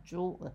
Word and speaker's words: Juul: J-U-U-L. Juul. How do you say that? – Juul. – Juul. I got it.